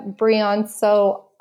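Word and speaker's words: Brianceau: My Instagram is Rebecca Brianso Art.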